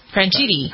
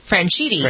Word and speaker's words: Franchitti: Ashley Judd and her estranged husband, Dario Franchitetti, is that how you pronounce his name? – Franchitetti, isn't it? – Franchitetti. Where's Roberta? – Franchitti. – How do you pronounce it? – Franchitti. – Franchitti.